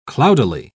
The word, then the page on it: cloudily: British English